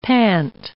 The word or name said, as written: pant